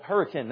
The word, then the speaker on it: Hurricane: About 20 years ago in West Virginia in a town called Hurricane. Now, we don't have It's spelled Hurricane, but if you pronounce it Hurricane, they're going to know you're from out of town.